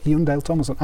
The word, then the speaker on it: Jon-Dahl Tomasson: It's an absolute pleasure to have on the 4000 Holes podcast the Rovers manager, Jon-Dahl Tomasson. Have I pronounced that right? Is that remotely like? – It was perfect.